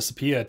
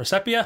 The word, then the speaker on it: sepia: How do you pronounce it? – I say sepia. – Is it sepia? – That's what I say. I don't know. – I used to always pronounce it sepia when I was younger, but sepia works, both of them.